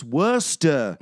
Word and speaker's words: Worcestershire: I actually did have a British roommate who told me, he's like, it's pronounced Worcester. It's Worcester.